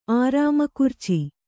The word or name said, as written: ಆರಾಮಕುರ್ಚಿ